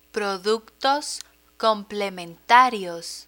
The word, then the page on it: productos complementarios: Spanish